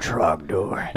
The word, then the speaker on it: Trogdor: I hope I'm saying it right. Is that how it's got to be Trogdor. – Yeah, it's got to be Trogdor. – And you got to lower your voice in octave.